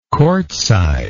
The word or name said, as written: courtside